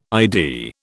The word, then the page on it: ID: British English